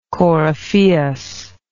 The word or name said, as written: coryphaeus